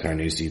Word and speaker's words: Carnoustie: It's actually pronounced Carnoustie. That was Carnoustie.